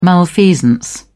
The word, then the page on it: malfeasance: British English